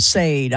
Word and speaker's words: Sade: Yeah, I said it wrong on live on radio. I called him Sade. I'm like, oh, I never lived that one down. So, yeah, Sade.